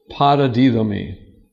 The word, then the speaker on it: paradidomi: The Greek word is paradidomi. And the English translation is like this. It's a long O.